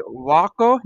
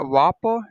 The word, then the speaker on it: Walker: My name is Waco, not Wapo. I mean, it's spelled out for you. WALKER, or you can call me Glory Crown.